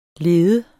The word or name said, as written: leder